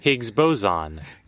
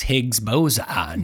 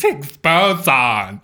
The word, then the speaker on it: Higgs boson: Higgs boson. – No, it's Higgs boson. – Yeah, why is he such a jerk? It's Higgs boson.